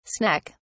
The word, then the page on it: sneck: British English